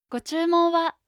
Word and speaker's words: ご注文は: «Го-чумон-ва».